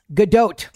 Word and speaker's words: Gadot: Gadot. The T is pronounced.